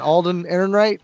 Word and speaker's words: Alden Ehrenreich: I'm butchering his name, man. Alden Ehrenreich? – Yep, there you go.